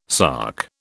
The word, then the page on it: sark: British English